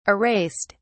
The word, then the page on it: erased: British English